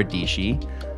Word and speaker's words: Dietschy: Today, we have Sara, Dietschy, rhymes with peachy.